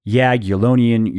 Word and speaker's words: Jagiellonian: For example, studies conducted by scientists at Aalborg University, the National Institute on Aging, Jagiellonian University Medical College, and yeah, I pronounced that one correctly.